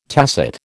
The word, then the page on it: tacit: American English